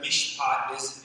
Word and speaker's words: mishpat: And there's a Hebrew word there, mishpat, is how you say it in Hebrew.